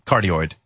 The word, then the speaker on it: cardioid: And so they ran that hoax where they, you know, isolated the microphone that was a corduroy. How do you pronounce it? Cordless microphone? – Cardioid. – Cardioid. That's it.